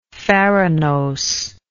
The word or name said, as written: farinose